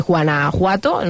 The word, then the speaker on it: Guanajuato: Guanajuato.